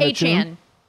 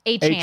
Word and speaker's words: Achane: Now, look, how do you say that? Is that Chun? Is that how you say his name? Devin Achane. – Achane. – Achane. Yeah.